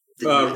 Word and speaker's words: Ralph Fiennes: There's a Coriolanus with, I think, Ralph Fiennes. – Ray Fiennes. – Ray Fiennes. – Don't pronounce the L. It's real weird.